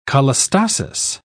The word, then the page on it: cholestasis: British English